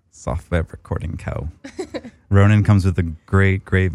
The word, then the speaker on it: Rōnin: Rōnin. – There's a character on top of that first O. – That character means you got to say it like a horse.